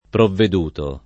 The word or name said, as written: provveduto